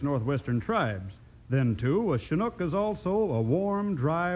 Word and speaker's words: Chinook: It's Chinook. That's C-H-I-N-O-O-K, and pronounced Chinook.